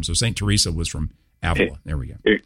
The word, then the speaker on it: Avila: Somebody said Avila. It's a villa, isn't it? Or how do you say it? – St. Teresa of Avila.